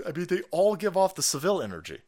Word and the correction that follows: Savile: Savile, I think it's Savile. Whenever I fuck up Jimmy Savile's name, which I just did right now, a whole bunch of Brits go, it's Savile, rhymes with gravel.